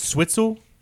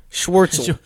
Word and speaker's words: Schwartzel: Is Switzel is his last name? – Schwartzel.